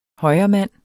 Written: højremand

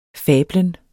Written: fablen

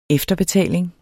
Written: EFTERBETALING

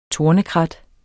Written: tornekrat

